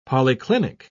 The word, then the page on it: polyclinic: British English